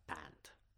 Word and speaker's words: Pant: Rishabh Pant.